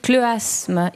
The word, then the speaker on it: schleuasme: Qui dit vrai à propos du schleuasme? – Alors... C'est difficile. Je vais choisir Philippe Battaglia, parce que je pense que ça se prononce... Schleuasme, en effet.